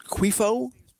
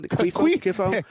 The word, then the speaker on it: Cuiffo: So right now, they got, I think his name is pronounced Quifo. C-U-I-F-F-O. Quifo.